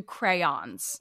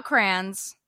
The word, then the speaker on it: crayons: There are two syllables to crayons. – Yeah. – They're not crayons.